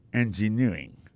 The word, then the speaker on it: engineering: Engineering, ça, l'accent tombé les mêmes sur avant dernier syllabe là.